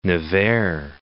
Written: Nevers

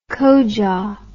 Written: kojah

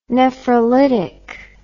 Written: nephrolytic

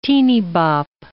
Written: teenybop

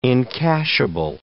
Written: encashable

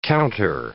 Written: counter

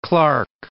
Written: Clark

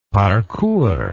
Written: parcours

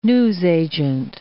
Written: newsagent

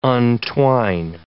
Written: untwine

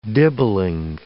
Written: dibbling

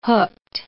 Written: hooked

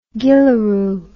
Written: gillaroo

